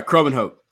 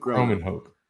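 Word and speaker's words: Kromenhoek: And what's the pronunciation on that last name? – Oh, sorry, Cromenhoek. Luke Cromenhoek.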